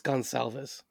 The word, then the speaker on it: Gonsalves: Matt Gone Cavs. He's got small calves. Michael Dowd, Jawan Briggs, Quantez Stiggers, Mike Jarrell, Cooper DeJean, George Holani, Carlton Johnson. – So I actually got pronunciation on it. It's Gonsalves.